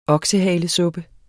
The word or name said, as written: oksehalesuppe